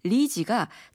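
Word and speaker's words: 리지가 (栗子): 그러니까 중국말로 밤을 뜻하는 리지가 자식을 세우다는 뜻의 한자 입자의 중국 발음 리지와 같아요.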